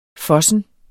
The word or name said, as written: FOSSEN